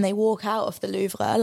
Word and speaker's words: Louvre: When they go to the Louvre.